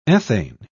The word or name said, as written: ethane